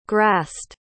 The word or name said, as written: grassed